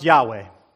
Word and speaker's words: Yahweh: When the word is pronounced, it's pronounced Yahweh.